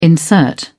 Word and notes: insert: British English

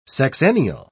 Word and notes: sexennial: British English

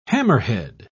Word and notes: hammerhead: British English

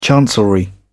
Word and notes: chancellery: British English

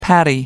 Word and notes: patty: American English

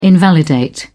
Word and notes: invalidate: British English

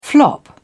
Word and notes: flop: British English

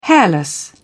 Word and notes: hairless: British English

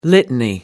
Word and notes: litany: American English

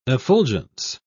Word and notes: effulgence: British English

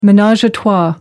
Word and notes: menage a trois: American English

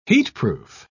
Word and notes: heatproof: British English

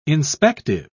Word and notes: inspective: British English

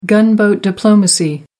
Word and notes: gunboat diplomacy: American English

